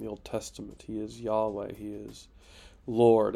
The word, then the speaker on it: Yahweh: You can see here on my screen, Lord, it's the Hebrew H3068, which is, we usually pronounce Yahweh. Some people pronounce it Yehovah, or other things.